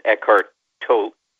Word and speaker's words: Eckhart Tolle: It's Eckhart Tolle. Is that how you say his name? – I think you say he pronounces it Eckhart Tolle. Tolle, okay.